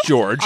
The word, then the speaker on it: Jorge: George Joestar, if you're looking for it on the internet, if you're looking for it on the internet, it's spelled like Jorge Joestar, J-O-R-G-E Joestar. Although the protagonist, George Joestar, explains it to you at the very beginning. He explains that it's pronounced George.